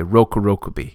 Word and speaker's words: Rokurokubi: So my recent special episode about a monster from Japanese folklore, I don't speak Japanese, and I read short O as being the English short O. And apparently in Japanese, a short O is still pronounced O. So the monster that I've been calling a Rokurokubi is a Rokurokubi.